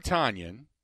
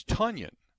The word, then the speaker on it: Tonyan: Apparently, Robert Tonyan is not Tonyan. It's Tonyan, and it rhymes with Funyan, if you're familiar with the onion treat.